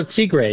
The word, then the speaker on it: tigre: I hope I'm saying this correctly. The tigres, it takes you... – Oh, a tigre. Yeah, yeah, yeah, tigre.